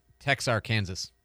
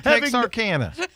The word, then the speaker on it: Texarkana: But just not to correct you too publicly, but I do believe it's pronounced Texarkansas. – It, really? – No. – Texarkana.